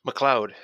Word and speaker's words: MacLeod: MacLeod. It's a Scottish name, like the Highlander.